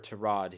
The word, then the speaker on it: Tyrod: We got Tyrod. Or to Rod, he said he'd like to be pronounced.